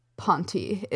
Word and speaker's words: Ponte: Anyway, the Cambridge Dictionary pronunciation says that... Ponty is correct.